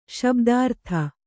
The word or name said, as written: ಶಬ್ದಾರ್ಥ